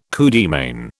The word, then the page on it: coup de main: British English